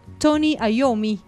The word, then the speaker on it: Iommi: sino que toca la guitarra el señor Tony Iommi.